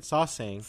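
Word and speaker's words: sasaeng: We've been pronouncing it wrong this whole time. – Saseng is what yeah. – I think we said. Saseng.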